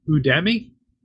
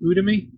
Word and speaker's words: Udemy: I did start, since our work has a contract with Udemy. Is that how you pronounce it? Udemy? Udemy? – I think so.